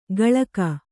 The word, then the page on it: ಗಳಕ: kannada; gaḷaka